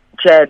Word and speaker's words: Djed: Djed, actually, just don't pronounce the D.